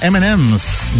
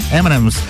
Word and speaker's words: M&M's: A foto tem traz o arame farpado do Post Malone e um M&M ao fundo, palavra que em inglês lembra muito a pronúncia do Eminem. M&M's, né? M&M's.